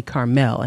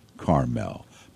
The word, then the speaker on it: Carmel: In my mind, it should be Carmel, because it has a reputation of being a very nice, wealthy community. – I remind myself by saying Carmel corn.